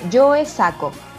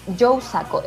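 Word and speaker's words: Joe Sacco: que se llama Yo, se escribe Joe Saco, Joe Saco, S-A-C-C-O, si mal no recuerdo.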